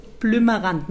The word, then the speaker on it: Blümmerrand: Ja, das ist für Hessen schwer auszusprechen. Blümmerrand. Man will immer mit P das aussprechen. Blümmerrand. – Blümmerrand. – Und es ist ja ein B. – Ja. – B. Kein P.